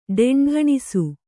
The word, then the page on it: ಟಂಟಣಿಸು: ṭaṇṭaṇisu